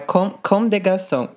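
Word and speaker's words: Comme des Garçons: Acho que é de É só o S do final que não pronuncia, do, do último, do D. É tipo Com de Garçon.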